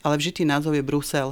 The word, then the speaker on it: Brusel: A to isté je aj pri názve mesta Brusel. Píše sa s jedným S, výslovnosť je s U.